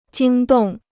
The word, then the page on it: 惊动: jīng dòng